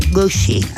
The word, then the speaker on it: Gucci: Ellos lo pronuncian Guxi. algo así. Hoy ya estuve como ocho horas así como Guxi. Gucci.